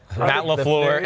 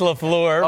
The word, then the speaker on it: LaFleur: Matt LaFleur. It's LaFleur.